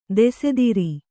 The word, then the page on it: ದೋಸ್ತಾರ: Kannada